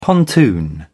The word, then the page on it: pontoon: British English